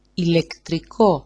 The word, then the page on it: ilektriko: ilektriko-